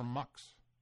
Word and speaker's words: Mx: And you pronounce it mix or mux.